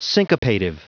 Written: syncopative

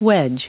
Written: wedge

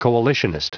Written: coalitionist

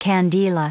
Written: candela

